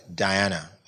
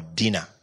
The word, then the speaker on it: Dina: Simeon and Levi decided to pay back the rape of their sister Diana, or Dina. Dina is actually the correct pronunciation of her name.